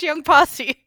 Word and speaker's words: Young Posse: Were actually, before we hit record, we were actually trying to figure out if Young Posse is how you say it. – And I'm so glad it's not how I would say it or anyone else would say it in America. – But we looked at the Hangul. It is, in fact, Young Posse.